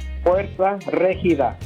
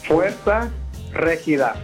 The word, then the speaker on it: Fuerza Regida: Fuerza Regida. – Say it one more time for me. – Fuerza Regida. – Fuerza Regida.